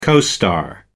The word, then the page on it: co-star: American English